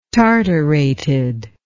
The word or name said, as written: tartarated